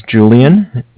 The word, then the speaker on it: Julian: And my name is Julian. It's J-U-L-I-A-N.